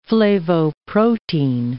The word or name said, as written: flavoprotein